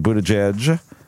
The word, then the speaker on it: Buttigieg: This results from two separate tweets that both Warren and Buttigieg, that's the right way to say it, isn't it? – Yes, it is.